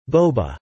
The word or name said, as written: Boba